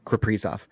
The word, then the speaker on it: Kaprizov: Karpatsov. Right, that's that how you say his name, Kirill. – Kirill Kaprizov.